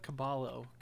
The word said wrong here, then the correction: Cabello: Caballo. – Oh my God. – Oh my God. Yeah. You mean Camila Cabello? – Yeah, Jesus. How did you butcher that so bad?